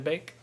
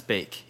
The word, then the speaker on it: Beek: Van de Beek? – No, it's Bake. Yeah, oh, it's Bake. – Donnie Van de Beek. – It looks like Beek. – It looks like Beek. – But it's pronounced Bake.